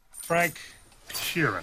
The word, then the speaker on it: Frank Sheeran: Frank Sheeran. Am I saying that right? – Yeah, you said it right.